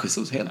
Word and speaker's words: Jesus: Gabriel Jesus i City. – Ja. – Som snyggt uttal där, annars får ni med mejla. – Ja, nej, Jesus, helt korrekt.